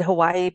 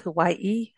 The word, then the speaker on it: Hawaii: It's you know, we've always said Hawaii, but it's Hawaii, Hawaii, Yes.